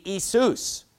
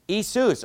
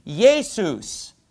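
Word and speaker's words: Isus (Ἰησοῦς): If you were to pronounce the Greek word for Jesus, it would be Isus. Isus. Or they say, in ancient times it was pronounced Yesus.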